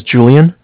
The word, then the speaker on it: Julian: My name is Julian. It's J-U-L-I-A-N. And Bank of Cardiff, it's spelled C-A-R-D-I-F-F, as in the word different.